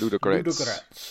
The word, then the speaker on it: Ludogorets: It's like Ludo. – Yeah. – Gorets. – Ludogorets. There you go, Ludogorets. – That's what I'm going to go with, Ludogorets.